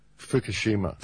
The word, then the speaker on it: Fukushima: Fukushima.